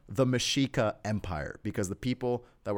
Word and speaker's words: Mexica: Mexica, it's spelled like Mexico with an A.